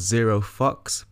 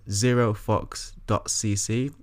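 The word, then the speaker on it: Zero Fuxx: The brand is called Zero Fox, but it's spelled F-U-X-X. So it's Zero Fox.cc, and his cycling socks are really, really nice.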